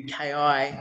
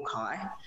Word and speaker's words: Ki: And I think I picked the name, I shortened my previous name to KI. And that actually has a very significant meaning in Korean. It can either be pronounced Ki or Kai. I prefer Kai, but I picked it because it actually means to begin.